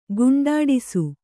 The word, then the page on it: ಕೊಂಡಾಡಿಸು: koṇḍāḍisu